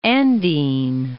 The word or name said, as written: Andine